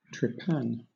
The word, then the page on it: trepan: British English